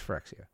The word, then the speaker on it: Phyrexia: He was mispronouncing Phyrexia. What did he call it? Phyrexia. He's Yeah. – like, we're going back to new Phyrexia. I'm like, it's Phyrexia.